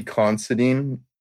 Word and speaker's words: Considine: Constantine? – Mills or, no, Constantine. – Mills, no, yeah, bro. Not Mills. It's like Paddy Considine.